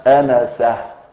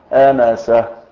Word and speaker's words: أنسة: فمن موالي رسول الله صلى الله عليه وسلم الذي قد لا يعرف الناس عنه شيئا على الإطلاق، رجل يسمى أنسة، مش أنس، زود في الهاء في الآخر التاء المربوطة، التاء إيه المربوطة، فمنهم ينطق الاسم كما أنطقه لك هكذا أنسة،